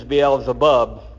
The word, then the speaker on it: Beelzebub: And attributed his casting out demons to him being in league with the ruler of the demons, Beelzebub, is how it's pronounced in the New Testament.